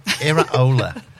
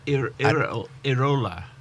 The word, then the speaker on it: Iraola: Iraola. – What? – Iraola. It's a second name. – Iraola.